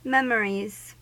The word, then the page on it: memories: American English